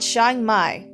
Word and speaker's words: Chiang Mai: Okay, it's called Chiang Mai. That's how you say it.